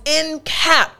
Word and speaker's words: encapsulate: So the first part we have is encap, right? Then the second part, salate, encapsulate.